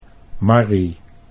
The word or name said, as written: Marry